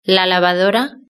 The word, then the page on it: la lavadora: European Spanish